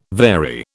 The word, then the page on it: vary: British English